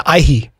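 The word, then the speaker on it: aihe: The Aihi. That's how you say it in Te Reo Māori.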